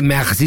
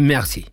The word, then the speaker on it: merci: Y no se dice merci, se dice merci.